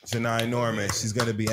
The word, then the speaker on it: Janai: Shout out to Janae Norman, Janai Norman, I believe is how you say her name. Janai. Uh, ABC News announced Janai Norman as the new co-anchor for Good Morning America Saturday and Sunday broadcast.